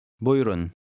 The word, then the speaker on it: Buyurun: The middle U is silent. – Buyurun.